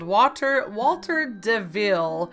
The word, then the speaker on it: DeVille: Walter DeVille. And it wasn't lost on me that it sounds like Walter Devil. It's pronounced Day, D-E, and then the second half of the last name, Ville, V-I-L-L-E.